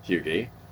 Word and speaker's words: hygge: Hygge? What is it? – Hygge.